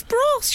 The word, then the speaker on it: Bros: Well, I got criticised for pronouncing the name of the band Bross, by my goddaughter's best friend Lulu, who said, it's bros. – Oh, it's bros, you idiot.